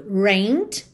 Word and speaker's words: rained: No passado, rained, escreve rained, mas o E não é pronunciado, tá? Então você vai direto do N para o D.